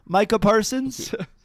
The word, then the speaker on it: Micah Parsons: Oh, you mean the NFL's most elite and versatile defensive player, Micah Parsons? – Yeah.